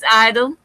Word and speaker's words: idol: let's talk about idol, which is apparently how we pronounce it. Is the G is silent. It is idol.